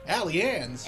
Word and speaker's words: Allianz: Was the first match at the Loons' new home, Allianz. Is that how you say it? – Yeah. – Allianz. – Minnesota is always Allianz. – Allianz.